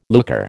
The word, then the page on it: looker: American English